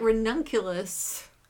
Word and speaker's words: ranunculus: I've heard that ranunculus, I'm probably not saying that right. – No, that's right.